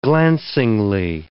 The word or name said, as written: glancingly